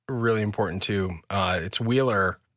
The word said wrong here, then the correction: Wyler: Am I saying that right? – Wyler. – Okay. Wyler brings a good point that every Jedi has a trial specific to them to become a knight.